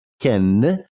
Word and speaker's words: Cannes: That's how it's pronounced. It's not pronounced Cannes, it's pronounced Cannes.